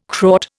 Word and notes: Kraut: Indian

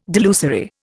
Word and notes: delusory: Indian

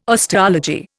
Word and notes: osteology: Indian

